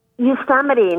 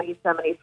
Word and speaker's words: Yosemite: like Yosemite, not everybody knows how to pronounce Yosemite.